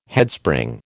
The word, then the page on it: Headspring: American English